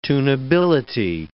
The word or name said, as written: tunability